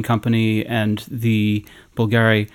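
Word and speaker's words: Bvlgari: Bulgari. – You don't pronounce the V, it's a U.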